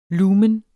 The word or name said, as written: lumen